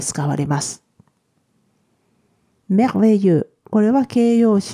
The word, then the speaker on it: merveilleux: え男性系の方が Merveilleux 女性系は Merveilleuse です。